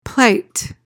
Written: plight

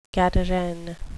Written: gadarene

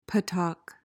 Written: puttock

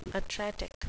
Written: atretic

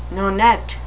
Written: nonet

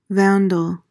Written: vandal

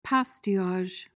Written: pastillage

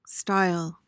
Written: stile

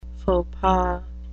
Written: faux pas

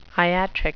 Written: iatric

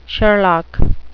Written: sherlock

